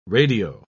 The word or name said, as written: radio